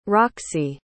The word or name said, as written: roxie